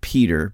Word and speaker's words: Petr: I believe that it's pronounced Peter, but it's spelled P-E-T-R, Svab. S-V-A-B, S-V-A-B,